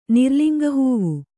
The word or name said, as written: ನಿರ್ಲಿಂಗಹೂವು